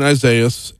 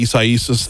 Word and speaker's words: Isaias: So where we're at right now with Hurricane Isaias, and yeah, no, I refuse to do the Isaiasis thing because it's spelled I-S-A-I-A-S. If you were to spell a name I-S-A-I-A-H, that would be Isaiah. You're swapping out the H for the S, but it's a different country of origin.